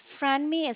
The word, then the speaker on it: Franmi: It's Franmi. It's spelled F as in Frank, R-A-N-M-I.